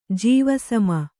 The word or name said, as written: ಜೀವಸಮ